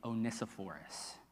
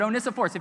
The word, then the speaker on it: Onesiphorus: I'm talking about a man named Onesiphorus.